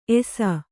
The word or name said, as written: ಏಸೆ